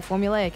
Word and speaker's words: formulaic: You actually just said it right. Formulaic is correct.